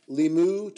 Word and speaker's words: Limoux: Sunday, stage 15, Limoux to Foix. I know OUX or OIX, that's how you pronounce it. That's the only thing I know about French. – Well, because it's like a roux.